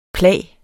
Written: plag

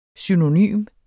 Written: synonym

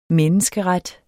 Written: menneskeret

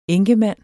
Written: enkemand

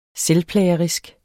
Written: selvplagerisk

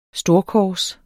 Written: storkors

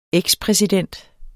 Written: ekspræsident

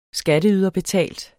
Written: skatteyderbetalt

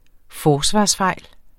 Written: forsvarsfejl

